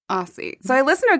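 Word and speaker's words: Aos sí: A-O-S, new word, S-I, and there's like a accent over the I. – It's the Irish pronunciation is Ishi.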